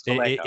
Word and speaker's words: Vallejo: Boris Villaggio, and I should know how to pronounce that properly, Julie Bell. – I think it's Vallejo.